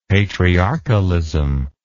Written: patriarchalism

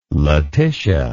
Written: Laetitia

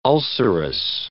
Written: ulcerous

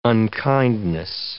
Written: unkindness

